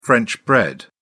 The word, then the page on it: french bread: British English